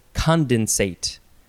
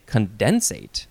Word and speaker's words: condensate: It is the word condensate, and it looks like you can also say condensate if you want to emphasize that syllable. – I want to. – You can. C-O-N-D-E-N-S-A-T-E,